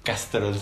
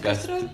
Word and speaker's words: Castrol: Según yo es Castrol. Castrol, Castrol. Castrol, es Castrol.